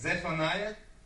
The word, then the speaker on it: Zephaniah: I don't know how to say the name of the book. It's Zephaniah. Yeah, right.